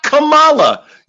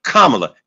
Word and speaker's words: Kamala: always putting the accent on the second syllable. That I don't like. That bothers me. Her name is not Kamala. You should understand this. Call her Kamala, as in what you'll be calling her a few months from now, Vice President Kamala Harris.